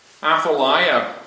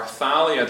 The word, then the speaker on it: Athaliah: And then, sometime later in the history of Israel, in the land of Judah, Athaliah, or Athaliah, depending on how you want to pronounce it,